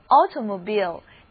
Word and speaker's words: automobile: 这个就是 mobile phone， mobile 的意思就是移动的，而动员就叫做 mobilize Mobilize，而会移动的就是会自己移动的，那就是汽车，叫做 automobile。注意字母 I 的发音变成了长的 E，移动就用单词 move 来表达，它的名词形式是 movement，电影叫做 movie。